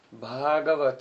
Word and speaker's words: Bhagavat: So all the Sandarbhas are called Bhagavat Sandarbha with long A, first A is long, and within them the second Sandarbha is called Bhagavat Sandarbha.